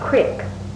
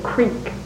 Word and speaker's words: creek: Yeah, but you and Granny Sarah used to call it a creek. Pronounce it with an I. Mm-hmm. They pronounce it with the E. We say creek with the E, but Granny Sarah used to call it a creek.